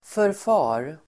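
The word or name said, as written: förfar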